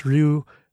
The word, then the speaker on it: Ryu: Okay, by the way, I know his name is pronounced Ryu.